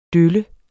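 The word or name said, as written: dølle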